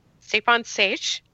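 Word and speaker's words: Stjepan Šejić: Stjepan Sejic.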